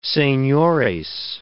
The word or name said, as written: senores